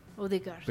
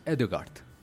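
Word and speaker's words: Ødegaard: Y hay un jugador que tiene el apellido Odegaard. – Odegaard. – Pero es Edegaard.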